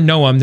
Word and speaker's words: Noem: It's Governor Noem.